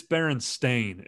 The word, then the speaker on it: Berenstain: It's not Berenstain spelled like Berenstein or Berenstain. It's Berenstain. It's spelled the Stein, Stein, Stain part is S-T-A-I-N.